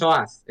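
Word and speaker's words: Oath: Que no, que la pronunciación es OAT. Es una promesa, es OAT.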